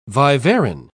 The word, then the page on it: viverrine: British English